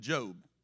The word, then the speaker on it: Job: And if you think you've gone through some things, just go read the book of Job. It is pronounced Job, not Job.